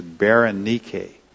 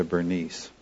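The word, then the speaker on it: Berenike (Βερενίκη): All right, the Greek pronunciation of her name is Berenike, but it's sort of anglicized into Bernice.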